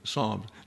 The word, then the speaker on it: Psalms: I once heard a guy pronounce that Psalms, but nevertheless, it's just one syllable, Psalms. Not Psalms, Psalms.